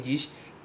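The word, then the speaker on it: di (時): A frase 3 diz o seguinte, Kodomo wa kuji ni nemasu. As crianças dormem às 9 horas. E aqui, esse kanji se pronuncia di, ele significa hora, kuji, 9 horas, kuji ni, às 9 horas, marcando a hora em que algo acontece.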